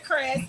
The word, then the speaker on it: Kryss: It's Alicia Chris. So my first name is spelled in train, and then K-R-Y-S-S.